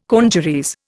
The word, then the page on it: congeries: Indian